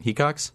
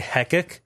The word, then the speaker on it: Hecox: That's how you say it, right? Hecox? – You said it right. – Okay. – Oh, what is the usual, like, go-to for the fuck-up of your last name? – Hecox? Well, I had a teacher that I think they're afraid to say the word cock. So they would say Hecock. But a lot of, like, Hecox or whatever. I don't know. It's like, have you never seen the word he before? – Has someone ever pronounced your first name Ian?